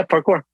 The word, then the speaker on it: parkour: Yeah, parkour.